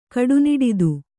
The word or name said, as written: ಕಡುನಿಡಿದು